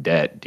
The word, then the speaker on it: debt: The fuck is up with the silent B in the word debt, dude?